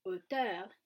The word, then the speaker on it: auteur: Auteur, or as it usually is pronounced in French, auteur,